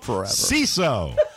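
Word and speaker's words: Seeso: Our show today brought to you by CISO.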